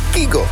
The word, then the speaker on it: Kygo: Wist je dat we Kygo al jaren verkeerd uitspreken? Het is eigenlijk Kygo. Maar goed, dat klinkt heel gek. Dus we zeggen gewoon nog steeds Kygo.